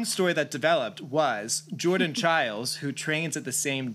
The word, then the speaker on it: Chiles: And Jordan Chiles, which rhymes with Biles, are both almost locks to be the Olympic team.